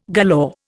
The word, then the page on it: galore: Indian